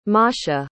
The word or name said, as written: Masha